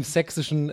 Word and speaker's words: sächsisch: Sächsisch, das ist ein weiches sächsisch. S. Ja, okay, sächsisch.